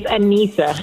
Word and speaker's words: Anisa: Did I pronounce it right? – It's Anisa.